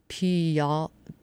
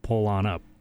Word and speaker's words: Puyallup: I used to say pull on up.